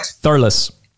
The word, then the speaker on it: Thurles: Thurles.